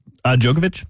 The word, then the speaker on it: Djokovic: Djokovic.